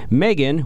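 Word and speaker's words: M3GAN: Is it pronounced Megan? – Megan, yes.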